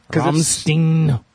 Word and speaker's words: Rammstein: So he's living in North Albany, Hans is still, but he's listening to Rammstein today. – Du. – Rosenrot. – Du Hans. – Du Hans. Du Hans, listen to Rammstein. Is it Steen or Stein? – Rammstein. – Okay, good.